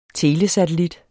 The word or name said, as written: telesatellit